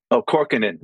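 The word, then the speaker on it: Kaukonen: Korkkonen, right? He told me how to pronounce it. I was like, every time I said his name, I said it wrong.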